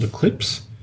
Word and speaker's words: Eclipx: It's called Eclipse, and it's spelled E-C-L-I-P-X. The stock code is E-C-X, Echo Charlie X-Ray.